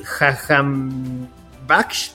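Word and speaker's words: Jahanbakhsh: Y después viene un despeje del arquero rival que le cae al iraní Jajambaksh.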